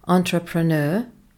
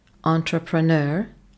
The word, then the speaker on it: entrepreneur: Ez egy francia eredetű szó, kiejtése entrepreneur, entrepreneur, hangsúly az utolsó szótagomban.